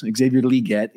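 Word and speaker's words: Xavier Legette: Xavier Leggett, he told us that was how he wanted to be pronounced.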